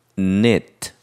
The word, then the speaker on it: knit: 그래서 코바늘을 넣다 해서 뜨개질하다 이런 뜻인데 옛날에는 K 발음을 했어요. 그러나 K는 묵음이 되어서 knit 이렇게 하면 되는 거죠. 그럼 이렇게 코 바늘에 실을 넣는 거, 그래서 매듭을 만드는 거를 K-N-O-T, 거기 어원프레드 나왔죠.